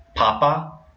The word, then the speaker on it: papa: What that sounds like to me is in Spanish, where we have the accent marks, where like papa, where you're stressing the first syllable is potato, or if you add the article, it might be the Pope. And if you do papa and you stress the second syllable, you're talking about a father or dad.